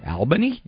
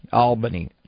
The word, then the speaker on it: Albany: Albany. Like Albany. There's a second L there. – Yes. Albany. I mean, I know that's the capital. I know that's the capital. – Yes, it is. – But I hear it pronounced both ways. – Yes, because it's spelled phonetically Albany. – Okay, but we pronounce it Albany. Like there's enough, a double L in there.